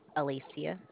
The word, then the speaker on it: Alicia: Alicia. – Could you spell that for me, please? – A-L-I-C-I-A. – T-I-A? – C as in cat, I-A.